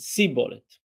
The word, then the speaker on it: Shibboleth: And it just so happened that these two tribes pronounced the name of the plant, Shibboleth, in slightly different way. One of them said Shibboleth, and one of them said Sibboleth.